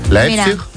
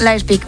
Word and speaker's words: Leipzig: ¿Lo he dicho bien? – Qué importante es saber idiomas. – Tengo aquí a mi amigo que me lo dice. ¿Cómo se dice? – Leipzig. – Leipzig. ¿Ves? – Leipzig, muy bien.